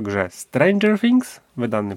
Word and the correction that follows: Stranger Things: Ale, ale akcent Stranger Things. – Stranger, Stranger, Stranger Things.